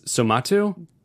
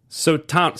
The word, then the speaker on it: Suotamo: Yunus Somatu? Somato. Sotanamo. – Yunus... – Suotamo.